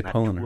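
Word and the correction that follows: Paulaner: Yeah, as we're finishing up this polliner, we'll get the pronunciation. – Paulana. – So we were kind of emphasizing the wrong syllable.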